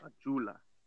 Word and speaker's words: ayuda: Ayuda. Like D-A. Ayuda. – Ayuda. – At the end, you say D-A. Ayuda. – Ayuda.